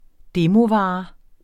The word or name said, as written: demovare